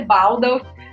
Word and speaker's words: Baldauf: Se, é, a pronúncia correta é Baldauf, mas como é um sobrenome alemão, eu não tenho nenhuma é, expectativa de pronúncia correta.